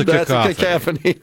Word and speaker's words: cacophony: That's a cacophony. – Exactly. Cacophony. Pardon me. It's C-A-C-C-A-C-O, not C-A-C-A. Cacophony. Pardon me. I mispronounced it.